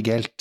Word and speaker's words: Zweigelt: og da har jeg en Schweigelt og da fikk jeg beskjed om at du må uttale altså zwei, altså to på tysk, zwei, zwei zweigelt, zweigelt.